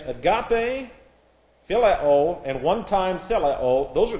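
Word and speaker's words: theleo (θέλω): This is T-H-E-L-E, long O, theleo, is used.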